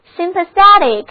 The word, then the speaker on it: sympathetic: 现在我们再在 sympathy 后面把 y 换成 etic 的形容词后缀，那么 sympathetic S-Y-M-P-A-T-H-E-T-I-C sympathetic 重音后移了，读作 sympathetic，意思就是同情的，出于同情心的。